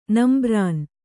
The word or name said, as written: ನಂಬ್ರಾನ್